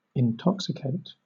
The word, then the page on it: intoxicate: British English